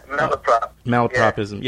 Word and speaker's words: malapropism: Still making malpropisms. How do you pronounce that? – Yeah, malapropism.